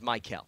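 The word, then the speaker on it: Mykell: Opposed to Michael because I'll give you the phonetic. It's M-Y-K-E-L-L. I break that down as Michael.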